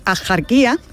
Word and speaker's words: Axarquía: Y aunque hoy la RAE deja claro, la RAE dice que lo culto y correcto es pronunciarlo con J, es decir, Axarquía, la tradición local sigue pronunciando y no solo escribiendo Axarquía o Axarquía, ¿no? Con una H aspirada, con una H aspirada.